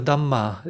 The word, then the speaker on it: Dhamma: And this path of the Dharma, or the Dhamma is the way it's pronounced in Pali, the early scriptural language of Buddhism.